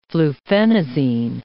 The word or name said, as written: fluphenazine